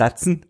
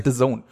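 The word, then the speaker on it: DAZN: Ach, so spricht man das aus. – Ja. Du meinst Datsen? Datsen. – Das heißt The Zone.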